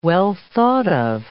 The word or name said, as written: well-thought-of